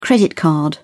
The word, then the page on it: credit card: British English